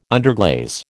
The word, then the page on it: underglaze: American English